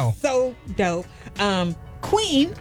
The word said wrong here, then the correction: KWN: well, the artist, her name is spelled K-W-N. – Right. – Her name is not Queen. Her name is K-Wan. I did not even know that. – But she pronounces it Queen. – No. They were saying K-Wan, and I was like, why did they say her name wrong?